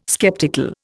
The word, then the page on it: sceptical: Indian